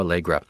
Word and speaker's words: Aalegra: Snow Allegra is the singer, and her first name is spelled S-N-O-H, Snow. And Allegra has two A's at the beginning, A-A-L-E-G-R-A, Snow Allegra.